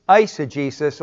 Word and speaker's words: eisegesis: Eisegesis, although I don't know why they say ice, because the diphthong is ace, so I'm going to say eisegesis, because that's how you pronounce E-I-S in Greek, but I'll go contrary to custom, but eisegesis or eisegesis, where you're reading into the text your own ideas, and that's not dealing honestly with the text.